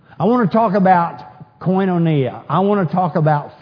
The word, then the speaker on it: koinonia: And really, instead of being pronounced Quantania, it probably should be pronounced koinonia.